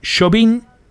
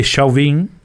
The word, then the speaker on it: Chauvin: Castellanizar un apellido francés. – En vez de Chauvin, es Chauvin.